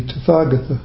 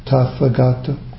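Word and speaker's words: Tathāgata: And so it is to meet the Tathagata, the Tathagata, sometimes pronounced. Thus come, thus gone. Realization in which this eye of meeting, I as E-Y-E, is already open in experience is called meeting Buddha.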